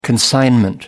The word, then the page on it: consignment: American English